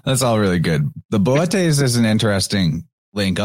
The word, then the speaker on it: Bootes: I'm also still crunching on that Bootes in Greek would be pronounced Votes, like the B. They don't do B in ancient Greek. It's a V sound.